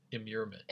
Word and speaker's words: immurement: Is it pronounced immurement? I think it's... – Immurement. – Immurement, yes. Okay.